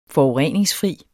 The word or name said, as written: forureningsfri